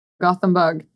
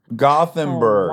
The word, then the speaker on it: Gothenburg: It's Gutenberg, yeah? Were you from? Is that how you pronounce it? – Gothenburg. – Gothenburg. – Oh, wow. – Yeah. – I fucked that up bad. I was like, oh, I bet that's a silent H, like Neanderthal, like German. I was wrong.